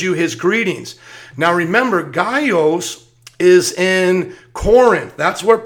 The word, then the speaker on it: Gaius: And then we hear about Gaius, and let's make sure we're saying his name right. Gaius, we're going to go to him in the Bible as well. He's Gaius.